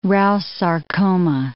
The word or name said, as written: Rous sarcoma